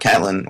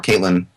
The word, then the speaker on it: Catelyn: But it's a sad thing, and it's a bad thing that this is what Catelyn, or Caitlin, depending on how you want to pronounce it, has become.